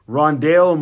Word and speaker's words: Rondale: If the Packers don't want Aaron Rodgers to be on Jeopardy forever, with a 29th pick, the Packers select who is Ronald Moore from Purdue. It is Ronald, right? Rondale Moore from Purdue.